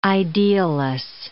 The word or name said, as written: idealless